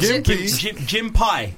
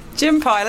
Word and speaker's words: Gympie: Is that how you pronounce it? Gympie or Gympie? – Gympie, let's I'm going to pronounce it Gympie because it's spelled G-Y-M-P-I-E.